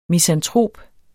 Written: misantrop

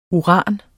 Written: uran

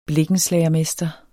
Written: blikkenslager

